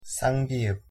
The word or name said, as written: ㅃ